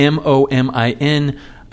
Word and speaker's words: Momin: However, the name of the brand is Moment, M O M I N. and I believe it's pronounced Momin. I hope I'm pronouncing it correctly.